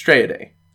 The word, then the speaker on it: Straya Day: It's Straya Day. Less syllables, right? – I guess, yeah. It's just Straya Day. – Straya Day. – No, don't say the A. It's Straya Day.